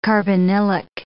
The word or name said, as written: carbonylic